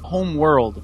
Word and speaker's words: Homeworld: Por favor, esse nobre, a pronúncia certa de Homeworld. – Homeworld. – Isso, muito bom.